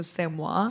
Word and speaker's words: c'est moi: I said it too, but it's French, so c'est moi.